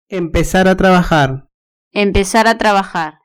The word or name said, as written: empezar a trabajar